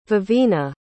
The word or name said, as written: vevina